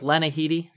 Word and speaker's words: Lena Headey: We go with Lena Headey. Is that how you pronounce her name? – I like that. I like that. I'm good with that.